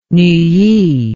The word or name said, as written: Neuilly